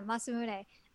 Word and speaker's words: Masamune: Yeah, Masamune.